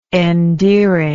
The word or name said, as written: endearingness